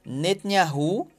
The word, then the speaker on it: Netanyahu: Netanyahu, gente, a é pronúncia é essa mesmo.